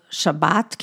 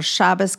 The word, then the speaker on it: Shabbat: Okay, so thank you for that introduction, and we will begin with lighting the Shabbos candles, which are called Shabbat candles or Shabbos candles, depending on how you pronounce it.